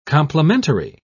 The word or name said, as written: complementary